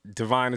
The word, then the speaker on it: Divine: Devine is spelled D-I-V-I-N-E.